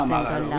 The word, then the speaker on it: Magaró: ¿Es Magaró o Magaró? – No, Magaró. – Ah, Magaró, bien. Lleva el acento, muy bien.